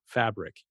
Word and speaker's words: Fabriq: It's called Fabric, but it's spelled F-A-B-R-I-Q, Fabric.